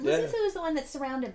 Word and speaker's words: Lesotho: The country Lesotho is pronounced Lesotho.